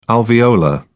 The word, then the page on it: alveolar: British English